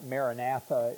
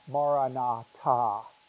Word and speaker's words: Maranatha: You know, the early Christians used to say, looks like Maranatha in your Bibles, but it's pronounced Maranatha. They used to say, Maranatha.